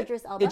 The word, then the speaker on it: Idris Elba: Sorry, is it Idris? I think is how you say it. – Idris Elba.